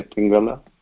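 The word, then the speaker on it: pingala (पिङ्गल): Yeah, now that la is totally gone. So totally gone means for most of the people in Sanskrit. – So just we say pingala. – Pingala only. Pingala now.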